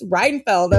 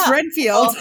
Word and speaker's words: Renfield: She lost her shit at me because she thought I was pronouncing Renfield syndrome wrong. She's like, no, it's Reinfeld. I'm like, no, it's not.